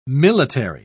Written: military

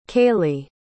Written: kaley

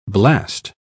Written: blast

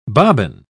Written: bobbin

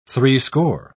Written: threescore

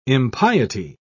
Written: impiety

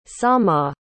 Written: samah

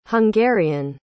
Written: hungarian

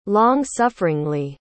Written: long-sufferingly

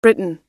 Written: briton

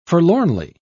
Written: forlornly

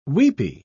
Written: weepy

